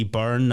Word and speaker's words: Beirne: And you can find me at Dean T. Byrne, that's B-E-I-R-N-E, for anyone who wants to have a crack at how my name is spelt. – Yes, thank you for pronouncing it correctly. It's Byrne. Byrne.